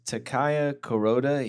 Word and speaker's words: Takaya Kuroda: His name is Takaya Kuroda.